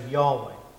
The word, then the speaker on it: Yahweh: And there's two current pronunciations, but we're going to say Jehovah, because that's the traditional translation. Modern people, some people say Yahweh, but we'll say Jehovah.